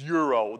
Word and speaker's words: gyro: Please don't argue with me about how to pronounce the word gyro. I've studied Greek, and it makes no sense. I just don't get it. It's Euro. Then we shouldn't spell it that way.